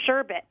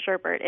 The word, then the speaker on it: sherbet: Sherbet. It's actually not sherbet, but everyone says sherbet. It's sherbet, no R in the last syllable.